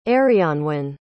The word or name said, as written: arianwen